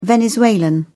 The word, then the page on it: venezuelan: British English